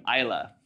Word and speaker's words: Islay: And Bruichladdich is a single malt distillery on the island of Islay, I-S-L-A-Y, Islay.